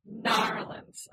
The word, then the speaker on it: New Orleans: So number one on here is the St. Louis Cemetery in New Orleans. – Narlands. – Oh, I'm sorry. Narlands, I guess is how you're supposed to say it if you say it right, like a local.